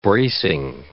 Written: bracing

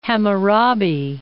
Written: Hammurabi

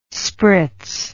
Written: spritz

